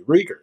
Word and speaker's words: Reagor: This is the first week I heard the other saying the pronunciation guide is actually Reagor.